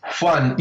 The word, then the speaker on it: Juan: Juan, yes.